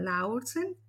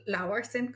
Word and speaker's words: Laursen: O puedes buscar el nombre de mi podcast, Cómo ser tú en un mundo complicado con Berenice Lara Laursen. O Laursen, como se pronuncia en danés.